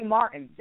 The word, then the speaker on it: Martin: You can find me on Facebook under the name Sheree Martin. That is spelled S-H-A-R-R-I-E, Martin, M-A-R-T-I-N.